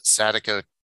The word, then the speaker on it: Sadako: But I have a Fantasia Fest coming up, and that has Sudoku 2, Sadako, how do you say her name? Not Sudoku, Sadako 2.